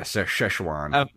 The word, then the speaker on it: Szechuan: Oh, so that's how you say it. – Yeah, Szechuan.